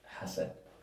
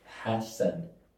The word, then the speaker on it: Hasan: How do you actually pronounce it? – Hasan. – What? – Yeah. – What? Say it again. – Hasan. So like you say the H. – Hasan. – Yeah. It's like Hasan, but Hasan.